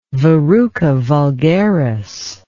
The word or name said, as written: verruca vulgaris